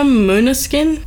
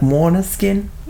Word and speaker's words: Måneskin: Also, Måneskin. I believe that's how you say their name. Måneskin? – Yeah, Måneskin. – Yeah, we checked and they pronounce it like Måneskin.